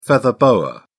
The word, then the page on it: feather boa: British English